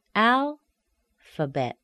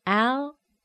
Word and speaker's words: alphabet: For example, alphabet. Alphabet. Al is a primary stress. F is a schwa. Bet is a secondary stress. It sounds like e, the short e sound. E. Alphabet. Next we have dynamite. Dynamite. Dynamite. Honeymoon. Honeymoon. Okay, the N-E-Y, the knee, it's a weakened sound, it's a weak stress.